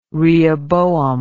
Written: rehoboam

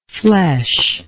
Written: fleshless

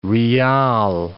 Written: riyal

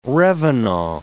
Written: revenant

